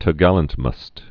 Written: main topgallantmast

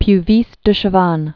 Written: Pierre Puvis de Chavannes

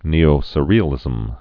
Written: Neo-Surrealism

